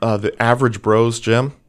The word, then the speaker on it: Broz: His last name is spelled B-R-O-Z, but it's pronounced Bros.